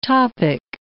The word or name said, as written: topic